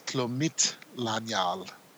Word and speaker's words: Tlumitlanyal: And that's Tlumitlanyal. The stress is on the M-I-T, accent on the I.